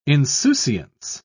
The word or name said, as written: insouciance